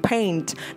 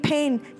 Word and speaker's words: paint: Ở đây mọi người để ý có một điểm đặc biệt trong từ paint. Đó là mình không đọc là paint paint giống như âm E ở trong từ pen của cây bút, mà mình đọc là paint ha.